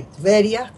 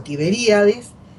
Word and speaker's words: Tiberias: Pero esto se escribe ahí en la Galilea, en una ciudad que está cerca de Tiberias, como dicen en Israel, o Tiberíades, que es donde se escribe este Talmud, y está compuesto por dos partes.